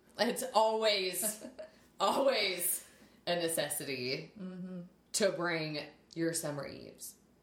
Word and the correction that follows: Summer's Eve: I'm saying that wrong. – Summer eves. – Or is it summer's eve? – Summer's eve. – Right. Yeah, whatever.